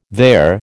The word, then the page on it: their: American English